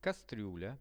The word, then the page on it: kastrula: Russian